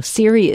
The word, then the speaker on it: Siri: But in case you're on the edge of your seat right now, Siri is just like Siri with an iPhone, S-I-R-I, and her last name is spelled H-U-S-T-V-E-D-T.